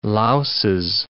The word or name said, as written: louses